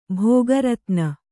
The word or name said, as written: ಭೋಗರತ್ನ